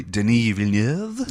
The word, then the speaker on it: Villeneuve: We are masturbating over Denis Villeneuve, or if you want to pronounce it properly, Denis Villeneuve.